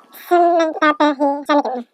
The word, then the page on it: simitaapihchaanikin: Chisasibi